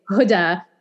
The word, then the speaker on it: Huda: It's such an easy name to say, Huda. Every letter exists in the English alphabet. And that nobody can say it right.